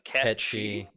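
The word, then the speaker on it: Cait Sith: Well, I mean, Kate Sith, which I've recently discovered, is. Apparently going to be pronounced catchy.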